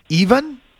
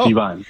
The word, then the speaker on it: Ivan: This is, okay, it's spelled I-V-A-N, but it's Ivan? Ivan? – It's Ivan. – Ivan. – Ivan. – I'm sorry. Is it Ivan or Ivan? – It's Ivan. – It depends on what language you pronounce. – This guy's already a little sketchy. – Okay, but in English it would be Ivan.